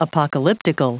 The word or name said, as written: apocalyptical